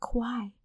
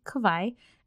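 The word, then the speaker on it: Kavai: And then I kind of looked at her and she's like, "Oh, did I pronounce it wrong?" I was like, "Yes, it's actually Kawaii."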